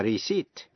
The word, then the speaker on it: receipt: A-C-C-U-R-A-C-Y, accuracy. Và precision, P-R-E-C-I-S-I-O-N. Cũng ôn lại những chữ dễ phát âm sai. như là chữ key, Q-U-A-Y, nghĩa là bến tàu. Debt, D-E-B-T, nghĩa là nợ. Và receipt, R-E-C-E-I-P-T, là biên lai. Phần chính của bài học hôm nay là cách phát âm tiếp vĩ ngữ I-O-U-S.